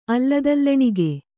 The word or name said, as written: ಅಲ್ಲದಲ್ಲಣಿಗೆ